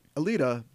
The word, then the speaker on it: Alita: We'll be back next week to talk about Alita, Battle Angel.